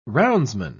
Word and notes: roundsman: British English

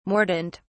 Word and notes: mordent: British English

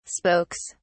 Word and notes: spokes: British English

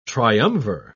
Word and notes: triumvir: British English

